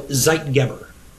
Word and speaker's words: Zeitgeber: So Zeitgeber.